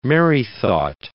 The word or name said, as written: merrythought